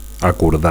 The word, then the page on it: acordar: Catalan